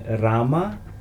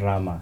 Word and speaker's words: Ram: So we are saying Ram. Not Rama. – Ah, se canta Ram, okay. Sin la A al final.